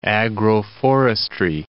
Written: agroforestry

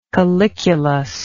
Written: calyculus

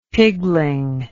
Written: pigling

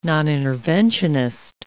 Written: noninterventionist

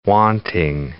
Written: wanting

